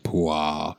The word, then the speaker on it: Puah: The king of Egypt said to the Hebrew midwives, whose names were Shiphrah, I think I said that right, and Puah. I need to say that like Stephen B. Stevens. – Shiphrah and Puah.